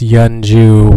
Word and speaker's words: Yeonju: Correction, it's Yeonju, Y-E-O-N, not Y-O-U-N-G. I think that's how I spelt it. But yeah, it's pronounced Yeonju with an N.